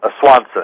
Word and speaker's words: Swanson: Swanson, S-W-A-N-S-O-N.